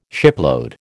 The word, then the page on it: shipload: American English